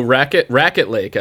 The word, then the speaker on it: Raquette Lake: So Raquette Lake, as it's actually pronounced, but that's a lake up in the Adirondacks where my family, no joke, used to camp every summer.